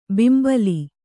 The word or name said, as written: ಬಿಂಬಲಿ